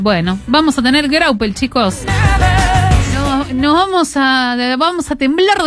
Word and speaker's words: Graupel: Graupel. – Sí. Se dice graupel. – Sí. – Una burra, yo lo dije toda la tarde mal. – No. – Yo lo leí. – Se sentó la primera sílaba.